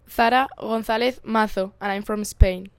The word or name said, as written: Zahra Gonzalez Mazo